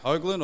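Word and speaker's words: Hoglan: Hoagland or Hogan or? – It's Hoagland, yeah. Hoagland, or Hoagland.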